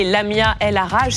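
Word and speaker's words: Lamia El Aaraje: Et on accueille pour en parler Lamia El Arage, c'est comme ça que ça se prononce Oui, tout à fait.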